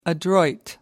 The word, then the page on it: adroit: American English